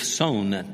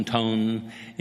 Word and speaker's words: Tson: One of the speakers was a fellow named Joseph Sohn, T-S-O-N. You can pronounce it Sohn, Tone, any number of ways.